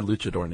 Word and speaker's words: luchador: That's my luchador name. – Okay. And you pronounce it correctly? It's not Luke. It's Luch.